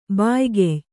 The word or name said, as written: ಬಾಯ್ಗೆಯ್